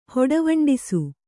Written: ಹೊಡವಂಡಿಸು

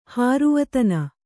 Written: ಹಾರುವತನ

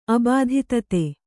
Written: ಅಬಾಧಿತತೆ

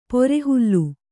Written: ಪೊರೆಹುಲ್ಲು

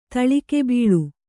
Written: ತಳಿಕೆಬೀಳು

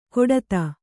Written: ಕೊಡತ

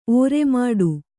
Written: ಓರೆಮಾಡು

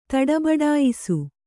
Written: ತಡಬಡಾಯಿಸು

